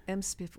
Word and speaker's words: MSPIFF: And did I say it right, MSPIF? – MSPIF. Well, yes. I mean, it is MSPIF. I know we wish it were something else, but it is MSPIF because it's easier to say than Minneapolis-St. Paul International Film Festival.